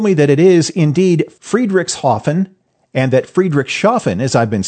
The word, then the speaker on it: Friedrichshafen: It's pronounced Friedrichshafen. There's no sh anywhere in there.